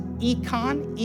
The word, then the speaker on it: eikon: That word image is econ, E-I-K-O-N.